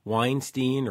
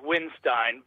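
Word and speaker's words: Windstein: Well, the correct pronunciation is Winstein, but you know, if you really want to dress him properly, you call him the Riff Lord or the Beard of Doom.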